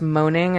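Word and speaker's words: Moning: It's called Fever, and the author's last name is, I think it's pronounced Moaning, and it's like M-O-N-I-N-G, I think.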